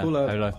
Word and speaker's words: Holo: Holo. Is it pronounced like that? – Yeah, Holo.